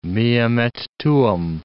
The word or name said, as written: meum et tuum